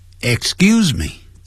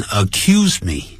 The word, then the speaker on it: excuse: 首先我们来看 excuse me，这个我们已经教过了，不要念成 accuse me， accuse somebody 那指控某人了，那是 accuse， don't accuse me，不要呢指控我。 excuse me 呢 excuse 后头念念死音，后头念 g Excuse me, 不要念成 excuse me.